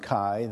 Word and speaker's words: Chi: And Chi, which looks like an X, but it's in the Greek alphabet, it's pronounced Chi,